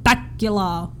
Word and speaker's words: Bakula: I, I feel like you're mispronouncing his name. – How am I supposed to say it? – Scott Bakula, just like that.